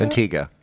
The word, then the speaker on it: Antigua: right south of Antigua. Is that how you pronounce that? – Antigua, yeah. Yeah.